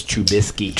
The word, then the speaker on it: Trubisky: You gotta learn how to pronounce the Polish name. – Trubinsky? – It's Trubisky. – Trubisky. Okay. Trubisky.